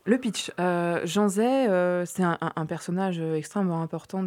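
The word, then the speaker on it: Jean Zay: Il y a une conférence organisée par Canopée Conférences le mercredi 22 novembre sur Jean Zé. Ça se prononce comme ça ? – Jean Zé, oui.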